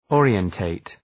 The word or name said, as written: orientate